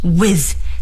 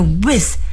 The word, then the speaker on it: With: 接下来再学一个形容词我们夸谁谁谁非常好就要说 Good, G-O-O-D 最后呢再来学一个介词 With W-I-T-H With 大家发这个 With 啊，要注意 TH 的发音，我们要把这个舌尖呢伸在上牙齿和下牙齿中间，同时呢还要产生摩擦 With With 是和谁谁在一起，和谁谁谁一道的意思。